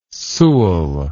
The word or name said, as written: Sewall